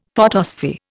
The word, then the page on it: photosphere: Indian